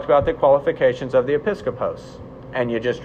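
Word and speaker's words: episkopos (ἐπίσκοπος): The original word, because everybody cares about this, is episkopos. Okay? And that's spelled, for you note takers, E-P-I-S. So E-P-I-S-K-I-P-O-S.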